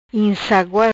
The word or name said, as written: Insagua